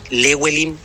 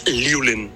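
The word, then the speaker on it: Llewellyn: yo recuerdo que en ese tiempo muchos pronunciaban Lewelin, Yewelin, y al escribirlo, ¿no es cierto?, intentaban ver cómo se pronunciaba. Yo un día les dije, el apellido es Liulen, y algunos se burlaron, pero así se pronunciaba. Se escribía Yewelin, pero se pronunciaba Liulen, ¿o no? – Muy bien.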